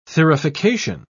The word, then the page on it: thurification: British English